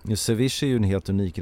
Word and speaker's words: ceviche: Precis, det är så man uttalar det, ceviche.